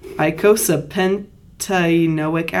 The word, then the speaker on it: icosapentaenoic: Icosapentaenoic. Did I say it right? Icosapentaenoic acid, EPA.